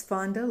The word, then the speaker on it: Fonda: Yes, Fonda, like Jane Fonda. And my name is Cherokee. I'm an eighth. And my middle name is Faye. The first name, Fonda, means profound.